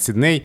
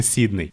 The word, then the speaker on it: Сидней: Это тот же самый вариант, как все говорят Сидней, и только немногие знают, что на самом-то деле правильно Сидней.